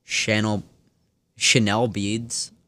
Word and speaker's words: Chanel Beads: Before that was Chanel Beads, I want to say. Yeah, that's how you say it.